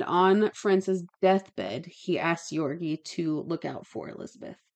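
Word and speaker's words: György: So, the investigation was handled by the king's highest ranking representative, Jorgi, I can't say it, the G is silent. – Jorgi Thurzo.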